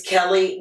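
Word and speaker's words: Kelli: It's Kelly with an I, Lemon, like the fruit.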